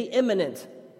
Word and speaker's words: immanent: Can you say imminent? Now, imminent is spelled I-M-M-A-N-E-N-T.